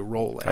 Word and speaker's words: Rowling: Rowling in the intro, but Trey informs me that it's J.K. Rowling. – I think. – So I'm rolling like bowling.